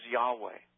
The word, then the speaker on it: Yahweh: Then there's Yahweh. This is the most important word, Yahweh, which is the approximate pronunciation of the holiest of names of deity in the Old Testament.